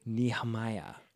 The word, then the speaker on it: Nehemia: Nehemiah. Well, it's spelled in a way that it could be like Nehemiah, but Nehemiah, I think, is more along the lines. – So it is pronounced Nehemia.